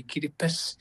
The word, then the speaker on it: Kiribati: Oh, it says we don't have the S in our language, so we say Kiribati.